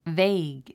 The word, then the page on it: vague: véig; ヴェイグ